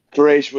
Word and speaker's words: Quraysh: Well, as I, on kind of that note, Colbert, the tribe thing, I know, like, the Quraysh, that was the, I don't know if I'm pronouncing that right. – Yeah, you did. – Quraysh was the big tribe, right?